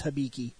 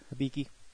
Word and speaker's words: Hibiki: And we're going to go into Kamen Rider Hibiki, which hopefully that's the correct way to pronounce it, considering how some of these shows aren't. – It's Hibiki. – Yep. – I'm almost 100% positive there's not a difference. It's Hibiki. – Hibiki.